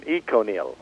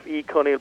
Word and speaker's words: Econyl: E-C-O-N-Y-L. – All right. – Which could be pronounced Econil, but we prefer Econil, so that's the way we're going to call it.